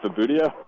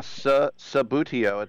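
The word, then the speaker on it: Subbuteo: Am I even saying that right? Sabutio? – Yeah, Sabutio. It's a weird word.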